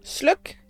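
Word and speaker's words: Sløk: Will give his last name a proper Danish pronunciation. – Sluk.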